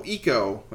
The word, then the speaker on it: Ico: Remember when the PS2 came out and they made that game Ico is how it's pronounced properly, about a little boy with those horns he's got, and he saves a princess, except maybe not.